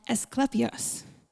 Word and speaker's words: Asclepius: The four cults that were represented in Pergamum were Zeus, Athene, Dionysus, and Asclepius. That's how you pronounce it. It is. I promise.